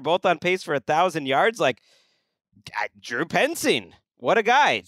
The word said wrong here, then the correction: Petzing: So did you – okay, so – is that how you say it? – That's P-E-T-Z-I-N-G, Petzing, I would say.